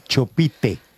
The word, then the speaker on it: Chopite: Su apellido, Chopite, ¿de dónde es ese apellido? – Realmente se pronuncia Chopit, perdona mi francés, porque...